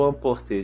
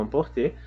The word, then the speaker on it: emporter: O verbo amporter, de fato, a pronúncia é emporter.